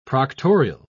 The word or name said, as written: proctorial